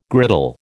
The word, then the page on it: griddle: American English